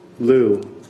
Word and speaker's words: Lyu: My name is Gary Liu. Last name is spelled L-Y-U.